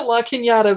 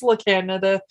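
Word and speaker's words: La Cañada: So they pronounce it La Canada, but it's La Canada.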